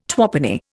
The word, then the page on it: twopenny: Indian